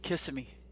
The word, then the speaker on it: Kissimmee: There you go, and then maybe I'll convince him the correct way to say Kissimmee. So. – Kissimmee.